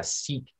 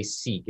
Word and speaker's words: Sikh: The crib notes is about 4 years ago in Kamloops, a Sikh gentleman. Now we say Sikh, it's actually pronounced Sikh. Okay.